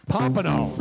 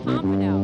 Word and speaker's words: Pompano: How do you say it again? – Pompano. – Pompano. – Named after a fish.